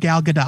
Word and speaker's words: Gal Gadot: Yeah, she does pronounce the point of order. It is confirmed Gal Gadot.